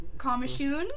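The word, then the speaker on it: Coimisiún: The largest Irish step dancing competitions, I'm going to butcher this hardcore, on Comishoon? – Comishoon. Very easy. It only took you 3 attempts. – Shut the front door. Yeah. Oh, I'm so excited. Comishoon.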